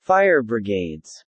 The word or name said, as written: Fire brigades